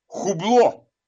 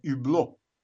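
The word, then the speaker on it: Hublot: Часы хубло. Это юбло. Это французское. Аж не читается в начале слова. Это юбло. Вот.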